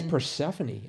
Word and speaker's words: Persephone: I think it's Persephone, actually. – Persephone. – Right. – Persephone, yeah. I was thinking it ended in an S, like Persephone's, but anyway, so spelling was a major...